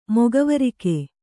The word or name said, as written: ಮೊಗವರಿಕೆ